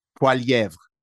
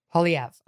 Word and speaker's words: Poilievre: What's his last, how do you pronounce the last name? – Poilievre. – Poilievre. Okay, Pierre Poilievre.